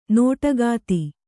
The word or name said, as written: ನೋಟಗಾತಿ